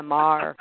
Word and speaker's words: Maher: I always say, like the political pundit Bill Maher. – Oh, Maher. Okay. – Yeah, Maher.